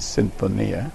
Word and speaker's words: Sinfonia: It's Spanish pronunciation is Sinfonia. The same pronunciation in Latin.